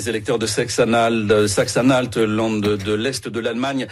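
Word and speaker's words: Sachsen-Anhalt: Saxe-Anhalt. – Alors, plus sérieusement, ce qui retient vraiment l'attention, c'est cette victoire de l'extrême droite en Saxe-Anhalt.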